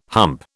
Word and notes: hump: British English

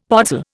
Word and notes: podzol: Indian

